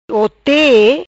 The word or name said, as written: Hote